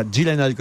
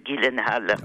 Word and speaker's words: Gyllenhaal: En tout cas, vous avez évidemment vu le film Prince of Persia, Les Sables du Temps, avec Jake Gyllenhaal, car il faut prononcer Gyllenhaal et non pas Gyllenhaal comme je l'ai fait hier ou avant-hier.